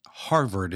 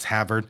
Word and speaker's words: Havard: Actually, Harvard is how I always spell it. No, it's Havard.